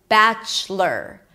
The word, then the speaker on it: Bachelor: And then the most important thing here is that you don't pronounce the O. It's not lore, but it's lur. Bachelor.